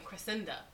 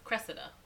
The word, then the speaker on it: Cressida: You're saying Cressida. It's Cressida.